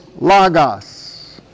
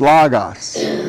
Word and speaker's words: logos: And that is logos. Most Americans say logos. But it's omicrons, not omegas. It's logos. That's L-O-G-O-S.